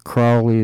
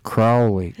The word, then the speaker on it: Crowley: If you think Crowley is holy, you say Crowley, but Crowley is if you think he's foully. So you pronounce it Crowley, like Grow, uh, Crowley.